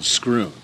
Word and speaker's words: Schroon: It's not, but you know, it's spelled S-C-H-R-O-O-N, not. – Oh, okay, like Scrooner. Like Scrooner tuna.